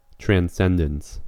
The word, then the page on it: transcendence: American English